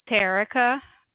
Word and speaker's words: Terrica: My name is Terrica with a T as in Tom.